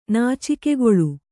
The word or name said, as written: ನಾಚಿಕೆಗೊಳ್ಳು